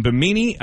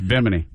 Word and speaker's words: Bimini: Owners of the Standard Restaurant in Toledo, Jeff and Megan, paid for a three-day Royal Caribbean cruise that included stops in Bimini, if I'm saying that right. – No, it's Bimini.